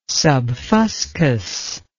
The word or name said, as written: subfuscous